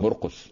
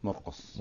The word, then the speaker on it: مرقس: مرقص بالسين ولا بالصاد؟ مرقص اه مرقص يعني بالسين تقريبا والبعض ينطقه بالصاد لكن هو يبدو بالسين مرقص مرقص آه.